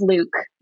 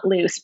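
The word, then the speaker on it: Luc: So he is absolutely pronounced Luke, not loose, but it is a fun double entendre that it's definitely not short for Lucifer.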